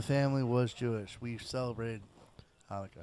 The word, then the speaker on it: Hanukkah: Hanukkah. You got to find something really deep in the throat.